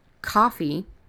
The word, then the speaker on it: Ko-fi: So that is coffee. And it sounds like the drink, but it's spelled different, as in Green Mile. But it is spelled it is spelled K O hyphen uh, F I dot com, and then you've got you know the the forward slash Holly Lisle.